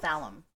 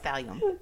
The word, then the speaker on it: thallium: Police tested the sample and found that it contained Thallium. – We have confirmed it's thallium.